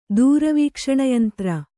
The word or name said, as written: ದೂರವೀಕ್ಷಣಯಂತ್ರ